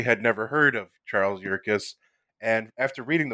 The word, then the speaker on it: Yerkes: Am I saying that right? – Yes, Charles Yerkes.